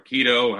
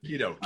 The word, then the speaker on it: keto: If you're into a number of different eating styles, vegan, dairy free, Keto or keto, however you pronounce it. – Keto. – Keto, I believe.